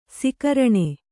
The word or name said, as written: ಸಕಾರಣ